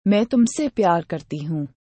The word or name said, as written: ma i tumse pyar karti hu